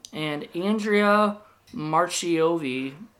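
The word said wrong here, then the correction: Marcovicci: or wait, Markovici. That's a hard name to say. – Let me see if I can pronounce that, that name? – Andrea Markovici. – Markovici, okay.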